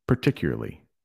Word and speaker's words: particularly: Particularly. So I say it more without the A at the beginning and more of an E.